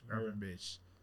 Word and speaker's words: Gravenberch: Gravenberch.